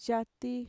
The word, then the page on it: yati: Mixtec